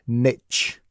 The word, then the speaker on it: niche: Now, first, if you're listening in the US and you're wondering what the heck I am talking about, then you will have heard it called niche. And that's just a transatlantic pronunciation thing.